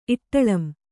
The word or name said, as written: ಇಟ್ಟಳಂ